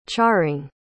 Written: charring

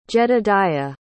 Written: jedidiah

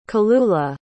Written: kelula

